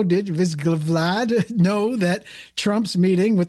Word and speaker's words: Vsevolod: Vsevolod. Vsevolod. Vsevolod. Vsevolod.